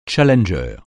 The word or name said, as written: challenger